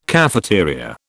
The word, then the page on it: cafeteria: British English